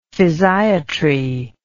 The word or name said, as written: physiatry